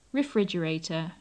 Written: refrigerator

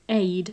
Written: aid

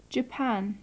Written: japan

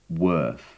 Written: worth

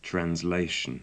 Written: translation

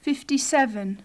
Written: fifty-seven

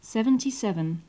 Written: seventy-seven

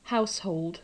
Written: household

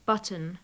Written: button

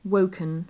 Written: woken

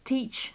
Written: teach

